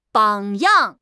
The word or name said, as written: 榜样